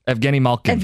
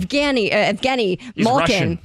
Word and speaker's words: Evgeny Malkin: Pittsburgh Pirates superstar, how do you say his name, guys? – Evgeny Malkin. – Evgeny Malkin. – Russian.